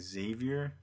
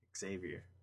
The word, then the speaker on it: Xavier: It was like that? Xavier. Like Xavier. You don't say X, you just Xavier.